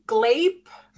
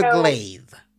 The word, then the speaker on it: glaive: And we noticed throughout the movie, some people said glaive and some people said glape something. Glaive, so just or I don't know. – Mispronounce or pronounce it several ways. – Yes. Yeah. – It is the glaive with a V.